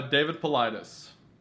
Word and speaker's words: Paulides: David Paul Leeds? Is that how you pronounce it? – Uh, David Paulides.